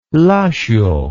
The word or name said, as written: Lashio